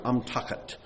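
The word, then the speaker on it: Amtachet (אמתחת): Amtachet is the word. Amtachet. It's pronounced that way.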